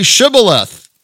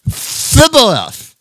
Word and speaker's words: Shibboleth: They'd say to him, "Then say Shibboleth." And he would say, "Phibboleth." For he could not pronounce it right.